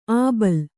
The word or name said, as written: ಅಬಳ